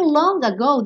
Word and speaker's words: long ago: Aqui o long ago, dependendo de como você cante, pode soar até long ago. Então esse dedo long, que quase nunca é pronunciado, nesse caso na junção com ago, ele pode até aparecer.